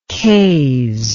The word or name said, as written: Kayes